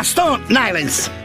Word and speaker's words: Stone Island: Questi jeans della Stone Islands. – Stone Islands. – Ma perché scrivono come si legge? – Si chiama così, Stone Island.